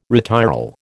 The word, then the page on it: retiral: American English